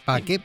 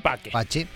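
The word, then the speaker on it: pace: Bueno, y siguiendo con la alusión funeraria, la banda que acabamos de escuchar se llama R.I.P., Requiescatin Paque, Pache, como usted quiera, si lo quiere pronunciar en la manera latina clásica, es Paque, si es a la manera eclesiástica, es Pache.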